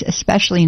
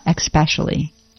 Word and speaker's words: especially: But there is no X in especially. It's especially not especially. Especially.